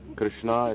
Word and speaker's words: Krishna: Krishna is for the female. – Na with a long A, that is the name of Draupadi.